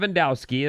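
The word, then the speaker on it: Levandowski: Lewandowski, am I saying that right? – Yep, absolutely.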